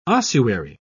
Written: ossuary

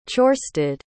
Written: ciorstaidh

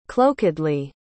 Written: cloakedly

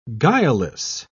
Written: guileless

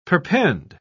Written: perpend

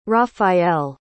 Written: raphael

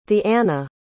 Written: theanna

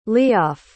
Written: leof

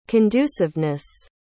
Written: conduciveness

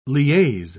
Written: liaise

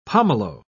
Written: pomelo

